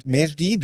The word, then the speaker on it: Mejdi: And the company is called Mejdi. Do I say that correctly? – Say that perfectly.